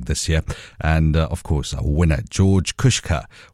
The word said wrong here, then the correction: Kusha: or George Kusha, that's how he pronounces his surname.